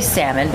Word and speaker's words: salmon: Well, I definitely say salmon because the L is silent.